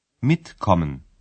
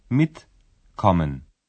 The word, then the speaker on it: mitkommen: Cette particule constitue la première syllabe du verbe lorsqu'elle est rassemblée, et c'est elle qui porte l'accent tonique. – Mitkommen. Mitkommen.